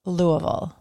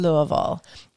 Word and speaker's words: Louisville: Louisville. – Okay, you said not right. Louisville. Right. Louisville.